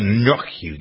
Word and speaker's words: gnocchi: People are talking about a new product at Trader Joe's this week, chocolate lava gnocchi. Can you pronounce that again? – It's gnocchi. It's not gnocchi.